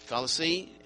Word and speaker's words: Colossae: In the church of what was it, Colossae? Is that how you say it? Yeah, Colossae.